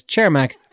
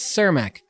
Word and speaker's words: Cermak: The Czech community here says Cermak, but the Chicago American community says Cermak.